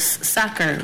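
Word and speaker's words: Sachar: and Holes by Lewis Sacker. Rhymes with cracker.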